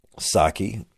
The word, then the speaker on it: Psaki: I just learned how to pronounce her name, by the way, even though it's spelled P-S-A-K-I.